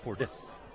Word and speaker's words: Fordyce: Stresses the Ford part. Fordyce. – Yeah, well, Fordyce. – That's the Williamsport pronunciation.